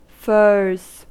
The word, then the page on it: furs: American English